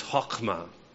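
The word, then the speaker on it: chokmah (חכמה): See, the Hebrew word for wisdom is chokmah. Not clearing my throat, that's actually how you say it.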